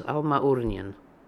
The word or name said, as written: Almaoornian